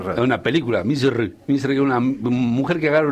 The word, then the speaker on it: Misr: Que han sido, por ejemplo, Egipto, en la lengua original, ¿cómo se dice? Misr. Y nosotros nada que ver, decimos Egipto.